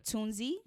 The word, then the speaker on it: Tunzi: Zazabini Tuzini. – Oh, boy. – Tunzi.